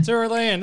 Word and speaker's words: Tyrolean: I even wrote it out phonetically, so I couldn't screw it up. The Happy Tyrolean Wanderers. – Tyrolean. – Tyrolean, there we go.